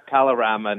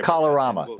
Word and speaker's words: Kalorama: So the, is it pronounced Kalamara neighborhood? – Kalorama neighborhood. – Kalorama, thank you.